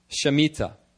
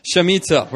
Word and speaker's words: Shemitah: In Hebrew, it's pronounced, I believe it's pronounced Shemitah. No response from the seminarians. Shemitah. We're going to go with it. Shemitah.